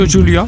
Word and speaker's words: Julia: Hanne en Julia, of Julia, hoe moet ik het uitspreken? – Ja, Julia. – Zeg maar Julia.